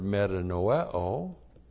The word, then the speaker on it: metanoeo: There is the verb metanoeo. And this is a compound word, metanoe, long O, metanoel. It has the preposition meta, that's the word to change. And noeo, that means to think.